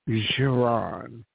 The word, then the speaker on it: Jeron: And I will pronounce it for you. It's Jeron. It's French. People call it Jereen and all kinds of names.